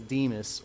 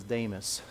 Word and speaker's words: Demas: I mean, granted, he's only mentioned like 3 or 4 times, and his name is Demas, or some pronounce it as Damas.